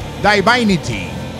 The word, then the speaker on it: Divinity: Hideous Divinity. No, siempre lo digo mal. Divinity.